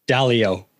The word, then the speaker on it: Dalio: Dalio.